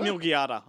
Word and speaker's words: Melquiades: I think it's Milgiada. – Because it's Q-U, but I don't know how they pronounce that in Spanish. Yeah. – Well, that's right. It's like quesadilla. It should be quasadilla.